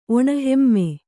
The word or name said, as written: ಒಣಹೆಮ್ಮೆ